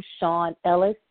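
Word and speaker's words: Schan Ellis: I'm on Facebook by the same name, Shawn Ellis, SCHANELLIS.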